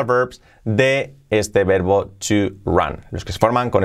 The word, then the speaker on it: run: Importante, run, la pronunciación que no es run, ra, ra, ra, la R, así, y el sonido es run, run, run, run, en pasado ran, ran, ea, ran, y en participio run, run, ran, run, así sería.